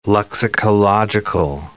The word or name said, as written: lexicological